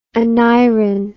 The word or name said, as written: Aneirin